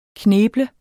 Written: kneble

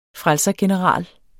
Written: frelsergeneral

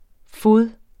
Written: fode

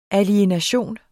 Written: alienation

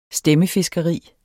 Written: stemmefiskeri

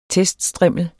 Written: teststrimmel

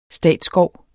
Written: statsskov

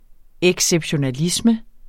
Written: exceptionalisme

